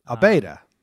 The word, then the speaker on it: Abeyta: How would you pronounce it? – The letters are A-B-E-Y-T-A. – Abeda. He's Abeda.